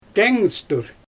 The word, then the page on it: teeŋstur: Xyzyl